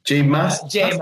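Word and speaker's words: J Mascis: How do you pronounce his name? – Jay Massis, yeah.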